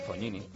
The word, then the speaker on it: Fognini: G antes de N, Fognini.